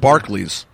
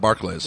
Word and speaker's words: Barclays: You know, Barclay Center, I was told yesterday yet again, is pronounced Barclays. The bank is pronounced Barclays, not Barclays.